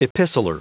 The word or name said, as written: epistler